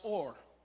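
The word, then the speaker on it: or (אור): So the Hebrew word for light is pronounced or.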